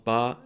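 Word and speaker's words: ba (八): Ja, 8 eh, wordt uitgesproken als ba en, eh, en, in, en dat trekt enigszins op het woord fa, wat betekent prosperous, dus eh, welvaart en, en weelde.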